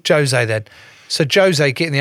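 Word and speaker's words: José: No, No, 'cause Portuguese. Portuguese. – What do you mean? – Jose. They pronounce the J's.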